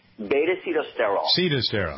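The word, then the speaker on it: beta-sitosterol: It has all of the things in it, the beta-cystosterol, is that how you pronounce that? – Beta-cetosterol, all right.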